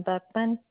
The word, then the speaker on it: Buckman: Marilyn Buckman, B-U-C-K-M-A-N. – You said Mary? – Marilyn, M-A-R-I-L-Y-N.